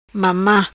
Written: Mama

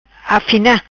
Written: Afina

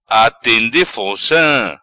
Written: Atende Fonca

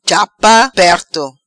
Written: Chapa Perto

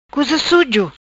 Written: Cuza Sujo

